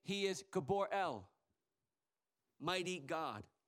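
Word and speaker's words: gibor (גיבור): Because here it's an, I think I'm going to pronounce it right in the Hebrew. The word mighty here in the Hebrew is a gibor. That's it. That's what he says, gibor.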